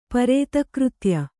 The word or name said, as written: ಪರೇತಕೃತ್ಯ